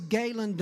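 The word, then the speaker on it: Galon: and my name is Galen. Nobody ever pronounces it right. It's G-A-L-O-N.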